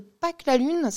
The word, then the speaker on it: PaQ'la Lune: Sur le site internet de Pâques la Lune, ça s'écrit P-A-Q-L-A-L-U-N-E, tout attaché, .fr.